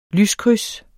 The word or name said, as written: lyskryds